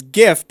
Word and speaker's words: GIF: 4781 says it's GIF, pronounced with a G, not a J.